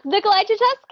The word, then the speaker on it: Nicolae Ceaușescu: I'm going to get this guy's name wrong, and I listened to how to pronounce and everything like five times, but Nicolae Ceaușescu. – Yes, Nicolae Ceaușescu. Okay.